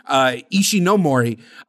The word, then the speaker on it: Ishinomori: This was a time when Ishimori I already mispronounced his name. That's fine. Ishinomori had been accepting work left and right,